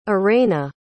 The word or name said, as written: arayna